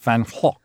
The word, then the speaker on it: Van Gogh: Well, it's in Dutch, it's pronounced Van Gogh, which is very difficult for the British to say. – It is, isn't it? – Yes, and that's why he wanted to be called Vincent, because he said foreigners murdered his name and couldn't pronounce it correctly. And we all pronounce it wrongly. The English, of course, say Van Gogh.